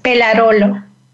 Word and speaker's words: Pellarolo: It's Pellarolo.